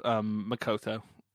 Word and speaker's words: Makoto: Yeah, she had a match with Makoto. Is that how it's said? If it's not how it's said, I'm going to be corrected. Earlier in the night. – Yes.